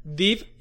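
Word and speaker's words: Dib: Div y Gas, se pronuncia así, de Invasor Sim, son mexicanos.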